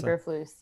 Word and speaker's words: Eberflus: Eric Bieniemy, Aaron Glenn, Arthur Smith, Matt Eberflus, and Eberflus, right? – Eberflus, yes.